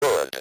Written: should

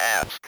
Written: ask

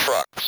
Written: trucks

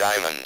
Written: diamond

